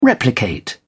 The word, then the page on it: replicate: British English